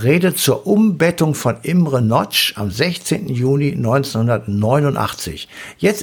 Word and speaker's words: Nagy: Geschrieben Nagy, falls irgendjemand. – Nagy, aber Nagy ausgesprochen. Also Nagy ist richtig.